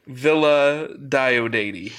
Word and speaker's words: Villa Diodati: And The Haunting of Via Diodati, or however you pronounce it. Villa, right? – Villa Diodati.